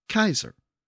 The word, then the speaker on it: Caesar: Well, during the period of classical Latin, Caesar was actually pronounced Kaiser. Remember that the C always had the K sound in Latin.